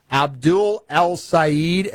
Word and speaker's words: Abdul El Sayed: Abdul El Sayed, am I pronouncing his name right? – You got it.